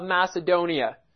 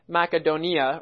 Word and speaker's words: Macedonia: So, Philippi and Thessalonica were both in the region of Macedonia. Or the correct way to pronounce it would be actually Macedonia or Macedonia.